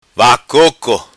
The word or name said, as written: vacoco